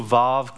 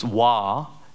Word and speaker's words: Vav: It's the Hebrew vav consecutive. Now, it's wa.